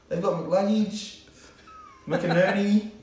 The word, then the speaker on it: McInerney: McInerney. – Yeah, that's pretty good. – Yeah, McInerney.